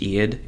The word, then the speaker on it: Ead: Her character's name is, I think it's pronounced Ead. It's E-A-D. – Mm-hmm. – Eadaz is actually her full name. It's like E-A-D.